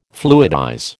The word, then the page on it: fluidize: American English